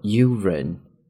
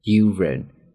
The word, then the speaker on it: urine: 注意这个单词读作 urine, urine.